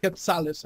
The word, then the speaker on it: quetzal: Quetzales, I think it is. – I don't know how to pronounce it. Q-U-E-T-Z. – Yeah, Quetzales. – Quetzal? – Yeah, Quetzal. So when you change that dollar to Quetzales, then that's a lot.